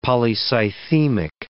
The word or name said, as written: polycythemic